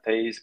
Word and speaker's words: Toews: I can tell you that people in Chicago are always very worried when Jonathan Tays, because we have to pronounce Jonathan, by the way.